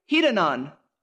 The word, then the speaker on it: hedonon: The Greek word is, I'm going to pronounce it right, hedonon.